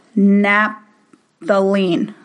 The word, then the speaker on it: naphthalene: So like if you use a air freshener in your bathroom, and it's, I can't really say this word that well, but it's called naphthalene. Am I saying it right? – You did it perfectly.